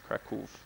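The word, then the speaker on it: Kraków: And the W in Polish is pronounced like a V sound, so it's Krakow, or almost like an F at the end.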